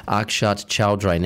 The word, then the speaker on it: Akshat Choudhary: And my special guest this week comes to us all the way from India, Akshat Choudhary.